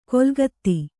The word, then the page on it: ಕೊಲ್ಗತ್ತಿ: kolgatti